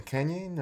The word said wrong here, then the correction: Kainan: Or how you? – Kainan. – Kainan.